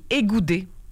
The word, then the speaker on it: Ekote: En fait, tu sais, ça s'écrit E-K-O-T-E et ça se dit égoudé.